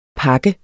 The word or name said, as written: pakken